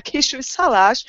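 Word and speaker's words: Kisújszállás: It's called Kisui Salas, and you have to stress it like this, like Kisui Salas.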